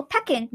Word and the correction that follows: Peking: No, it's pronounced Peking, Oh.